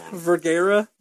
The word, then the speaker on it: Vergara: I don't think anybody cares about the them fights, but CJ for Viagra. – I think you just said that wrong. – Vergara, but I'm calling Viagra now.